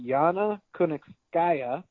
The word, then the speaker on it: Yana Kunitskaya: She's going to be fighting Yana Kunitskaya.